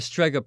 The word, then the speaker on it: Strega: the Strega Prize.